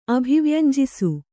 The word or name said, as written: ಅಭಿವ್ಯಂಜಿಸು